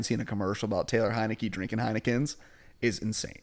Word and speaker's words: Heinicke: Heinicke, okay. I was going to say, how do you pronounce it? I was going to say Heineken, but that's not the...